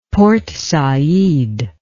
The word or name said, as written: Port Said